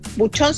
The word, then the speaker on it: Bouchon: Buchón se escribe Bouchon, así que una buena opción de probar algo distinto, un vino hecho de manera colaborativa por algunos rostros.